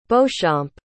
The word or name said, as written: beauchamp